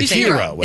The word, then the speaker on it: gyro: Yeah, and it's pronounced hero, just so you know. – Hero? – Mm-hmm. – It is? – Yeah. – I've heard gyro.